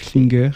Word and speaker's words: Klinger: Avec cette description de Sylvie Kling, je te donne la bienvenue, si je ne sais pas si bien prononcé. – C'est Klinger, mais tu Klinger. – Fais comme tu peux.